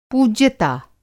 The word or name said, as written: पूज्यता